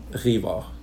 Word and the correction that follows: Rivard: Rivard. – Rivard. – Oh, Rivard. Oh, see.